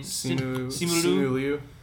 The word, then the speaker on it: Simu Liu: Like, the way Simu Liu, or however you say his name. – Simu Liu.